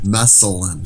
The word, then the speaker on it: Meselyn: Mesalyn. – Spell it for me. – M-E-S-E-L-Y-N.